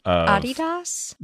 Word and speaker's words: Adidas: Adidas? – That's the German pronunciation of Adidas.